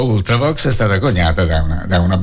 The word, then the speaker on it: Ultravox: Allora, messaggio di Marco, io presentando gli Ultravox, l'ho pronunciato all'inglese con la short U iniziale, e Marco dice: veramente Ultravox è una parola latina.